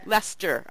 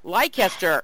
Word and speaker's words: Leicester: She is joining us from the School of Media, Communication, and Sociology at the University of Leicester. Am I saying that correctly? – Leicester. – Okay. – Yes. – See, if I'd never been to England, I would have said Leicester.